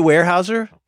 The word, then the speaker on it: Weyerhaeuser: Okay, so you guys, you sing the Weyerhaeusers up there. I think that's how you pronounce it. Uh-huh. Is it Weyerhaeuser? – Yeah, Weyerhaeuser.